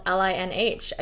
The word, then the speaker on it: Linh: I mean, my name is spelled LINH.